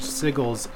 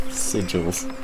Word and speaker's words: sigils: Obelisk of some sort, while the other is covered in strange sigils and disturbing... – Sigils. – I said sigils. Did I say sigils? – It's all right. It actually can be pronounced either way.